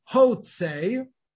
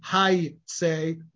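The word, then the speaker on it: Hayse (הוצא): Same on the left-hand side of the page, an earlier passage in Bereshit, where the word is spelled Hey Vav Tzadi Aleph, which I would read as Hotse, and the marginal note tells me, again, it alters the spelling and the pronunciation, telling me that it should be read Hayse rather than Hotse.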